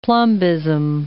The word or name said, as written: plumbism